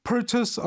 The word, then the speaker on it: purchase: 여러분은 이거 발음 틀리지 마요. Purchase예요. Purchase. 이걸 그 purchase로 읽는 분 많다 그랬죠.